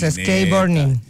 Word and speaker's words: skateboarding: Ah, los de patineta, por eso los skateboarding. – ¿Skate qué? – Burning. – Es que como Luchi fue a la Vega. – Digo yo que se dice así, Ronald, ¿no? Skateboarding, sí.